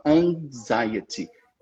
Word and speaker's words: anxiety: Na verdade, essa palavra, ela é uma tricky, porque a gente realmente tende a pronunciar aquele X assim, mas a gente não pronuncia, a gente fala anxiety. É para dentro. É quase um G que você não chega a falar.